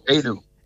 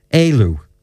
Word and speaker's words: Éalú: I say the leisure centre, pronounce it, the Irish. – Oh, Éalú. – Éalú. – Yeah. – Okay. – And so, Éalú means escape in Irish.